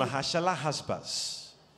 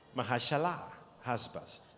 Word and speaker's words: Maher-Shalal-Hash-Baz: Maher-Shalal-Hash-Baz. Maher-Shalal-Hash-Baz.